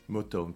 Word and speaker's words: Motown: Toi, ta société aujourd'hui, elle s'appelle Moton. – Motown, prononce bien, s'il te plaît. Motown Oui, presque. Ah, vas-y, redis-nous. Motown. Motown. – M-O-T-O-W-N, inspiré du fameux label euh, musical Motown.